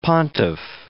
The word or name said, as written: pontiff